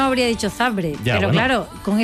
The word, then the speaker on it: Zabrze: Górnik Zabrze de Polonia 2, Mónaco 3, ganando el equipo monegasco. ¿Por qué sabe que es Zabrze? ¿Por qué no? ¿Por qué pronuncias Zabrze? – Porque es así.